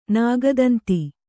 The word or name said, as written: ನಾಗದಂತಿ